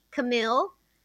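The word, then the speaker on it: Kamille: It's K-A-M-I-L-L-E, Camille, and then Bauer, B as in boy, A-U-E-R, like Eddie Bauer.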